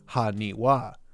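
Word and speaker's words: Haniwa (埴輪): This could be a bit of fun Japanese wordplay, as in Japanese, 828 can be pronounced Haniwa, as the number 8 can sometimes be read as Ha or Wa.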